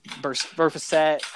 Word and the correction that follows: Burfict: basically killed Antonio. – What? Burfict.